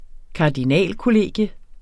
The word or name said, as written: kardinalkollegium